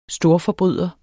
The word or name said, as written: storforbryder